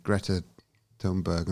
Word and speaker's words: Thunberg: I mean, at the moment we've got lone voices like, you know, Greta Thunberg, and I apologise if I pronounce that wrong.